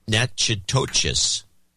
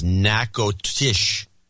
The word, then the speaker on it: Natchitoches: Mr. Peabody in Natchitoches, which is Nakotish. The Nakatish. Nakatish. which doesn't look like it, but that's what it's pronounced.